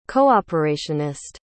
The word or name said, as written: cooperationist